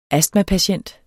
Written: astmapatient

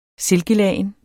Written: silkelagen

